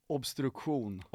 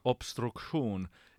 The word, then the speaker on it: Obstruktion: This band is called Obstruktion. I don't know. How do you say it in Swedish? – Obstruktion. – Obstruktion.